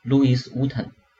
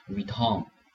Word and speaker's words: Louis Vuitton: The second one is Louis Vuitton. 路易威登，大家可能对这个路易威登有点陌生，但是呢，它的缩写 LV 大家一定特别了解。那很多人把这个 Louis Vuitton 读成 Louis Vuitton，但这个呢，法语的里面呢，前面的这个 s 不发音，后面呢要读成 Vuitton。